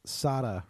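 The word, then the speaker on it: Sara: Sarah Ramirez, which also I will say, not only did we misgender, the actor's name is pronounced Sada Ramirez. It's Sada.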